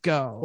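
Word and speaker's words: Geaux: I thought it was G-EAUX. Go.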